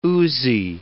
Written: oozy

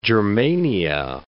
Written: Germania

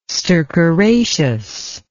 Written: stercoraceous

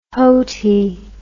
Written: houtie

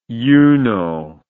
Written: uno

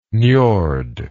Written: njord

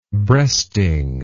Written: breasting

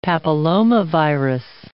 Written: papillomavirus

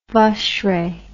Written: vacherin

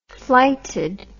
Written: flighted

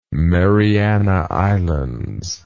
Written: Mariana Islands

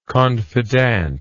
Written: confidente

